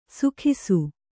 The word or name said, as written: ಸುಖಿಸು